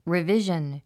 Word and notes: revision: rivíʒən; リビジョン

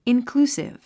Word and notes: inclusive: inklúːsiv; インクルゥスィブ

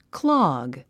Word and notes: clog: klάɡ; クロォッグ

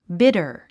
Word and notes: bidder: bídə r; ビダァ

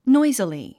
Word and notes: noisily: nɔ́izili; ノイズィリィ

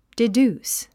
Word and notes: deduce: didjúːs; ディデュース

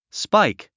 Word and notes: spike: spáik; スパイク

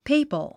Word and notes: papal: péipəl; ペイパル

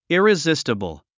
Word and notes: irresistible: ìrizístəbl; イリズィステボォ

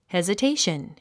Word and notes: hesitation: hèzətéiʃən; ヘズィテイション

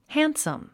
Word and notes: handsome: hǽnsəm; ハァンサム

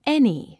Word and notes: any: éni; エニィ

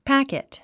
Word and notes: packet: pǽkit; パァケット

